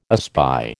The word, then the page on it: espy: American English